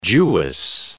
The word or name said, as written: Jewess